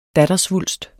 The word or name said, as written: dattersvulst